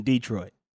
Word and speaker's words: Detroit: and I was his brother who lives in Detroit. – Oh, okay. in, in, in the South, you put the emphasis on the D.